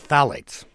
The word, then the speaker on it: phthalates: I don't even know how to pronounce this. It's P-T-H-A-L-A-T-E-S. Why don't you pronounce it for us? Tell us what it is and what it's in. – Yeah, this is an incredible substance called phthalates.